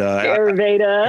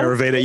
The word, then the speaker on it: Ayurveda: That's what is it? Avareda. I always pronounce things terribly, but Ayurveda. – Ayurveda. Yeah.